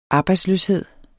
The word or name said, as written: arbejdsløshed